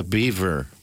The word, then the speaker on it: Biewer: The Beaver. – Uh-huh. It's not spelled Beaver. It's spelled B-I-E-W-E-R, but it's pronounced like Beaver.